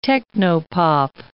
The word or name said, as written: techno-pop